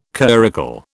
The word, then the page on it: curricle: British English